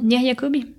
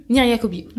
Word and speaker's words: Nir Yacobi: Sous la direction de Benedetto Piccoli et alors là, aide-moi, je veux pas mal prononcer euh, l'autre nom. Nir Yacobi. Nir Yacobi. Ouais. Aussi simple que ça s'écrit.